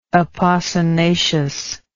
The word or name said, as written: apocynaceous